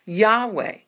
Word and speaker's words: Yahweh: Well, you're to praise Yah, J-A-H, and it's a shortened form of the four-letter Hebrew name for the Lord, which is Y-H-W-H, which is usually pronounced Yahweh.